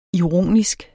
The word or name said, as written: ironisk